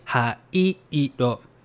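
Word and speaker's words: Ha-i-i-ro (灰色): 黒 black 黒 blue 青 blue 青 brown 茶色 brown 茶色 gray 灰色 gray 灰色 So for this one, gray, it's kind of tricky because you have two E sound next to each other, right? Ha-i-i-ro.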